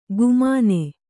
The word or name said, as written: ಗುಮಾನೆ